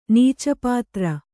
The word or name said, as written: ನೀಚಪಾತ್ರ